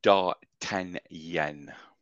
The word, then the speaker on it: D'Artagnan: I went to a very popular website and asked what the correct pronunciation was. – Yeah. – And it's D'Artagnan.